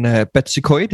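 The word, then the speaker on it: Betws-y-Coed: And also, it sort of relates to last week we went filming up in Betsycoid. Is that how you pronounce it? – That is correct.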